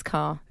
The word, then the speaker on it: Carr: Eloise Carr. There's no U in my name. Get that filthy U out of my name. And Carr spelt with two R's.